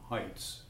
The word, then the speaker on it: Heitz: My male name is Tom Heitz, which is spelled H-E-I-T-Z.